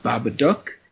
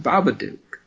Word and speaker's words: Babadook: Some people will tell you it's pronounced Babadook, and they are wrong. The movie will tell you that it's pronounced Babadook, and I disagree with that. I know that every other word that's spelled O-O-K makes an ook sound, but listen. Anyway, I think it was a very good...